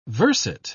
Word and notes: verset: British English

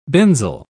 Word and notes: benzyl: British English

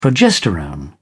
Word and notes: progesterone: British English

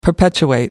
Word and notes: perpetuate: American English

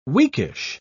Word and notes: weakish: British English

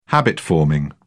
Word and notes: habit-forming: British English

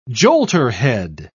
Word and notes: jolterhead: British English

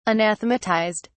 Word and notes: anathematized: British English

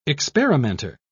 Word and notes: experimenter: British English

